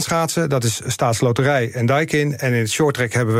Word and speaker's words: Daikin: En om ze recht aan te doen, zeg ik even dat het Daikin is.